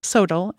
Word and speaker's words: SoTL: This is sometimes abbreviated SOTL, or pronounced SOTL, S-O-T-L.